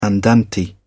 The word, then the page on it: andante: British English